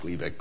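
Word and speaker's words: Glivec: Or in Europe, it's called Gleevec. It's also pronounced Gleevec, but it's spelled differently.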